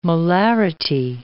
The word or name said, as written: molarity